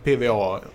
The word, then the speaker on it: PWA: Och vi har ju en, eh, vad ska man säga, High Profile eh, PVA. PVA är då förkortningen som egentligen borde uttalas PWA, men mm. så gör vi ju inte i Sverige.